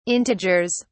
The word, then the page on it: integers: British English